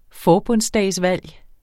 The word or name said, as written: forbundsdagsvalg